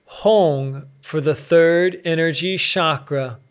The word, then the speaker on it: Hong: The sacred mantra and vibrational healing sound for the third energy chakra is Hong, spelled H-O-N-G, pronounced Hong.